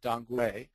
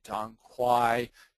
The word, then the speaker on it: dong quai: I believe they were using dongguei, which is more of the Chinese pronunciation. The American pronunciation might be dong quai. Dongguei is more of a Chinese pronunciation.